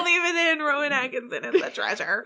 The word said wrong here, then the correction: Rowan Atkinson: Rowan Atkinson!